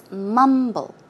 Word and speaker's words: mumble: The stress goes on the first syllable, for example. It's mumble, mumble.